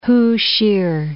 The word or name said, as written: Hu Shih